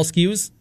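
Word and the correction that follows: Skenes: Skeens, yeah.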